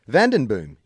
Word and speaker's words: Vandenboom: And I'm using his surname because he starts his letter by saying, Vandenboom, yes, pronounced as it's spelt, it's Dutch.